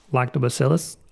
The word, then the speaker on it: Lactobacillus: Lactobacillus.